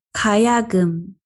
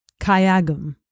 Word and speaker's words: Gayageum: Gayogum. – Gayogum.